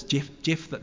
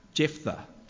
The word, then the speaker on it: Jephthah: Jephthah. There's three H's. How many names have three H's? Hands up if you've got a name with... No. Jephthah.